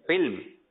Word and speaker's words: علم: جب تلفظ صحیح نہیں ہوتا علم, علم یہ چل رہا ہے. صحیح ہے علم.